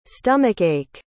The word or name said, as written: stomach-ache